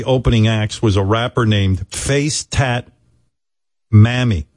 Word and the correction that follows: Mami: Mommy. – What? – Mommy, but it's spelled M-A-M-I. Mammy.